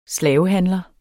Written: slavehandler

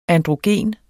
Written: androgen

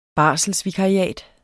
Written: barselsvikariat

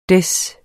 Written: Des

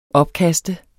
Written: opkast

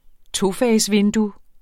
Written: tofagsvindue

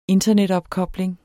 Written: internetopkobling

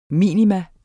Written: minimum

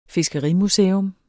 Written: fiskerimuseum

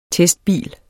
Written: testbil

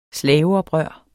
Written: slaveoprør

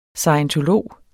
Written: scientolog

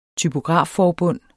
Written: typografforbund

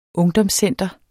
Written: ungdomscenter

